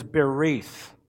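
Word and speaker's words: berith (ברית): The word used in the Hebrew is berith. Berith. So the word for covenant in Hebrew is berith. It's spelled B-E-R-I-Y-T-H, which means in the sense of cutting, a compact or a because made by passing between pieces of flesh.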